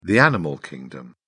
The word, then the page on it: animal kingdom: British English